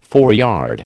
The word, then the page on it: foreyard: American English